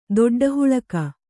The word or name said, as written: ದೊಡ್ಡಹುಳಕ